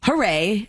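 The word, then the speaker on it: Hoorae: And if you go to her media company page, which is called Hooray, and it's spelled like Issa Rae, Hooray Media, she has a drama series all shot vertical, so you can scroll through it while you're watching it on TikTok.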